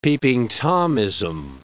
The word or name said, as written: Peeping Tomism